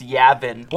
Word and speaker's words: Yavin: He's gonna kill me, but yeah, he pronounces it Yavin. That's not right. It's Yavin. – What? They've said it in the movie. – It's before. – Gavin with a Y, not fucking Yavin.